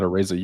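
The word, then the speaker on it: Araiza: Chiefs signed Matt Azaria. How do you say that dude's name? – I think it's Matt Areza. You're thinking of like the Simpsons guy.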